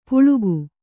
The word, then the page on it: ಪುಳುಗು: puḷugu